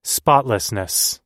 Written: spotlessness

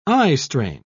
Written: eyestrain

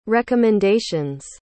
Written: recommendation's

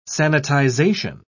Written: sanitization

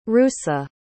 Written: russa

